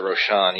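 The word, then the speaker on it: Rochon: And actually, Debbie Ronchin is I think, or Roshan or whatever. – Yeah, Jason called her, pronounced it Roshan. Yeah, Roshan.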